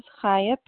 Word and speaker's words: Chaya: On the list, my name is spelled C H A Y A. That's the correct spelling with a P left initial. But you say it like you're saying hello.